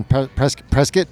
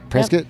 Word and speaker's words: Prescott: So they're in Northern Prescott, yep. Like biscuit. Prescott. Yep. Did I say it right? – Yep. Yeah. Prescott.